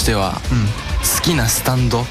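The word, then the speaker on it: 幽波紋: スタンドっていうのは、あのすいません説明させていただくんですけど、 3 部から登場したこう、あの漢字で幽霊の霊に波紋って書いてスタンドって読むんですけど、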